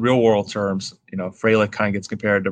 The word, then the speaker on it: Frelick: First of all, we have Sal Frelick, and I know it's pronounced Frelick because RJ did me the service of making sure we pronounced his name right, against Colton Cowser.